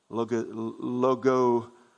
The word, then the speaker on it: logizomai: The word thinks, logizomai is the way you pronounce that.